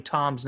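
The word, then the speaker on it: Thomm: It's T-H-O-M-M, Tom. It's a silent H and really a silent M because there's two M's. But it's T-H-O-M-M is how you spell his name, Drew.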